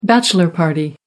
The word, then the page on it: bachelor party: American English